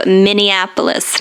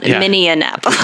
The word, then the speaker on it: Minneapolis: That and then also Minneapolis, not Minneapolis. – That's right. Yeah, there's not an extra syllable in there.